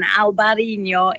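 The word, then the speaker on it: Albariño: Alvarinho is how they pronounce it, and Albarinho in Spain. It depends which side of the border.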